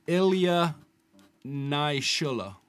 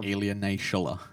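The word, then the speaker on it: Ilya Naishuller: Ilya Naishullah. Naishullah. – Where's he from? – Ilya Naishullah.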